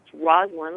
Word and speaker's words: Rosalyn: It's Rosalyn.